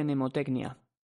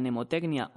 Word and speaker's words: mnemotecnia: Considero importante remarcar que la palabra mnemotecnia o mnemotécnica se escribe como suena, pero llevando una M delante. Esta M es muda, como la P en la palabra psicología.